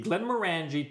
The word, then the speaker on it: Glenmorangie: Rhymes with orangey, Glenmorangie 10-year, fresh orange juice, Lapsang Souchong syrup, grapefruit bitters, and egg white.